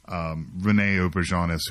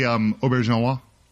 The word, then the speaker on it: Auberjonois: Also, I learned that I've been saying his name wrong my entire life. – Oh, he's bad. When you find that out, you know, wait. – I think it's actually Auberjonois.